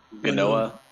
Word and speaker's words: Genoa: Genoa? – Genoa.